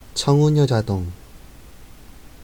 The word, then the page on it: 청운효자동: 韓国語